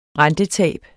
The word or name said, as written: rentetab